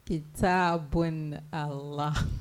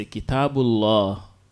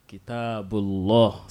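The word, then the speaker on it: Kitabullah (كتاب الله): Kitabun Allah. – Kitabu Allah. So you just you leave out the alif. So you say Kitabullah. Kitabullah. Say it. – Kitabullah. – Correct. Kitabullah.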